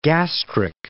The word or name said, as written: gastric